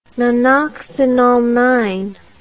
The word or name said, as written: nonoxygenated